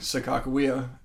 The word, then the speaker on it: Sakakawea: Actually, I didn't grow up ice fishing, but when I was growing up, we'd go up to Lake Sakakawea, and that's how you pronounce it.